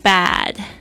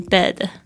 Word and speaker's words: bad: 那还有就是这个 bad B-A-D，注意一下那个 A 的声音呢，要小心一下，不要念成 bed，好像嘴巴都不愿意睁大、哦、 bed 就变成那个床 bad 就是好像就是去看那个牙齿的时候，那个不是会有这个压舌板吗？